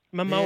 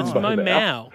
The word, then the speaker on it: Momoa: Momoa. Yeah, no, Momoa. It's Momoa. – It's Momoa. – Mimosa? – Isn't it pronounced Momoa? – Momoa.